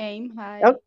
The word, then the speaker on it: Aim High: Bom, a primeira performance que a gente teve foi em High, né? É assim que se pronuncia? Ah, em High, em High. – Que. Em High? – É em High. – É, Aim high.